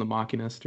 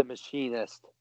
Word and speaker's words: Machinist: Or he did Christian Bale in The Machinist or whatever. – Oh yeah. The Machinist. – Is it not pronounced Machinist? – It is not. It is pronounced Machinist. He is a machinist. That is his job.